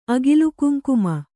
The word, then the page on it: ಅಗಿಲುಕುಂಕುಮ: Kannada